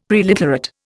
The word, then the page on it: preliterate: Indian